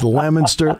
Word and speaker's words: Leominster: It is Leominster, Fitchburg, and Leominster.